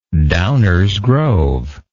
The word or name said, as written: Downers Grove